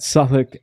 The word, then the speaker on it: Southwark: Yeah, Kevin, I've got to say, I'm very impressed with your pronunciation of Southwark, because that's not an easy one. That's one we like to trip people up with. So well done. You've got it.